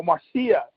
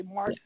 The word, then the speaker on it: Marcia: Oh, Marcia. I always say Marsha. Yeah. I gotta remember that, Marcia. I'm glad you said that because I call her Marsha. I gotta spell it phonetically so I don't mess up. Okay. – Right. No, I did that too. So, but you know, we had. She's Marcia. Marcia. – Marcia. – It's spelled the same way. It's just Marcia.